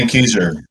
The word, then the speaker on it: Benckiser: Philips Electronics, Reckitt Benckiser, I'm not sure I'm pronouncing that right. – Benckiser. – There you go.